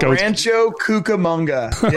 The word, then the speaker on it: Rancho Cucamonga: Because I didn't want to say it because I didn't think Rancho Cucamonga.